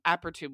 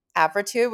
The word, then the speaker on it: Apretude: Okay, so so uh, tell us a little bit about Ap- Apretude, which is the prep I'm on. – Apretude, which is the prep you're on, which you pronounced perfectly, and as many of you know, in medicine, we like to use a lot of big words.